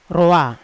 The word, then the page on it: roa: Yawa